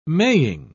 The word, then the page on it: maying: British English